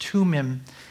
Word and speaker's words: Thummim: Thummim. And so they didn't know what to do. There's this thing called the Urim and the, it's actually pronounced Thummim.